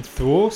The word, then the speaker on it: Thule: One that I haven't heard of is the German Thule Society. Is that how you guys would pronounce it? T-H-U-L-E? – That's how I would pronounce it, yeah.